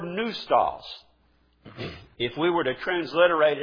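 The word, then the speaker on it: pneustos: Now, if you think about it, there are a few words in our English language that use that same combination of P-N-E-U and use just simply the sound of the N. Neustos is the way this one is pronounced.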